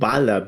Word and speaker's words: Vala: To tie the knot at the end, and there was another issue with Vala, because in Argentinian Spanish, you will pronounce it Bala, not Vala, and Bala sounds like bullet in Spanish,